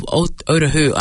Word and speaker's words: Otahuhu: And in the Otahuhu area, so in the South Auckland area of Otahuhu, I would say Otahuhu because that's how they say it in their language.